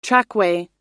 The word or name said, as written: trackway